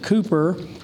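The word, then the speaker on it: Cowper: And what I'm going to read something on the first page, which it was a hymn written by William, they pronounce it Cooper, who William Cooper was a contemporary of John Newton.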